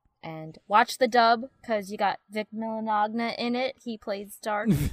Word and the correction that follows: Mignogna: Wait, how do you say his name? – It's Vic Mignogna.